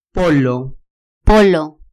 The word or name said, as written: polo